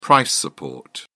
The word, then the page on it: price support: British English